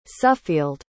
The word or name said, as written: suffield